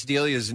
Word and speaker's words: D'Elia: By the way, some people are texting in and say Chris D'Elia's name is pronounced Chris D'Elia. You guys ever heard that? – Yeah, I have. Okay. I didn't know which way to go, D'Elia. So I went with D'Elia.